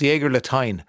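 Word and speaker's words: Jägerlatein: Erica says, the last syllable of the German word Jägerlatine is pronounced Clementine. Aha! So it's Jägerlatine.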